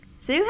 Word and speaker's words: Zhu: but it is actually spelled H-A-I-Y-A-N-G. Last name is spelled Z-H-U. I'm also going to be referring to him by his last name, and I'm going to be pronouncing it Zhu.